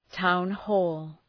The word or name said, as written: town hall